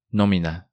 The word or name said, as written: Nomina